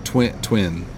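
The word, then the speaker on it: Twen: there's a band called Twin. It's T-W-E-N, Twin. Is that how you say it, right? – Sounds good.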